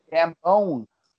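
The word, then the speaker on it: Gambon: The next will uh, movie, Michael, uh, how do you pronounce his last name again? Gambone. Gambone. Michael Gambone.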